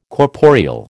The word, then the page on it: corporeal: American English